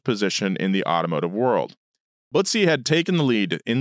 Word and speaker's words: Butzi: Butzi It's spelled B-U-T-Z-I. – Bootsy. – Bootsy.